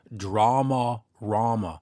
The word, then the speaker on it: Dramarama: I always say Dramarama, just my very sort of hick Canadian way of saying it, and I'm told, no, it's Dramarama. But saying it with such pronounced vowels is so slow and tedious.